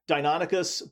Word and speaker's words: Deinonychus: Deinonychus. – Deinonychus. Say it again. – Deinonychus. Deinonychus. – Ostrom preferred the pronunciation Deinonychus, but honestly, you know, the dinosaurs are dead.